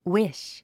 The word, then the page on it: wish: wíʃ; ウィッシュ